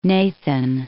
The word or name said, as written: Nathan